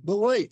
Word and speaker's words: Beloit: Beloit. He's my neighbor. – Oh, really? – Stillman Valley, just half an hour away. So we know Beloit.